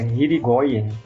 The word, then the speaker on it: Hirigoyen: é Hirigoyen, é, e aí ela definiu assédio moral num livro de 2002, a gente coloca a referência aí no post.